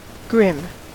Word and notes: grim: American English

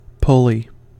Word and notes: pulley: American English

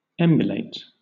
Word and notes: emulate: British English

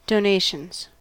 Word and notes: donations: American English

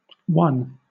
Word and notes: won: British English

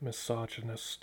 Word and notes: misogynist: American English

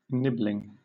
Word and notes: nibbling: British English

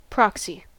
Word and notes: proxy: American English

